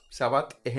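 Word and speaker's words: Savate: Se inventaron esto de arte marcial pasándose en, en, kickboxing que le llaman sabat. Sabat es el nombre del calzado que usan. Entonces literalmente es un... – ¿Cómo se escribe s a v a t s S-A-V-A-T-E. No, es Sabate. Sabate.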